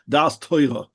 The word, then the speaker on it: Da'as Torah: I have to say it with a strong Ashkenazic accent. Da'as Torah. That is the only way to pronounce it.